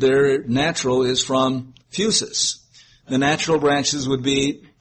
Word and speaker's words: phusis: Now, the word nature means, or is from the Greek phusis, P-H-U-S-I-S, is how I think we would probably pronounce it, phusis, P-H-U-S-I-S.